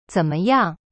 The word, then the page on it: 怎么样: zen me yang